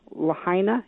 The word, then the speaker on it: Lahaina: Lahaina. Is that how we pronounce yes. – that? – Mm-hmm.